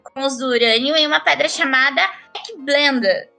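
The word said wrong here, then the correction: pechblenda: Gente, é, eu vou pronunciar não, assim. O nome, o nome é Peck Blenda mesmo. Peck Blenda.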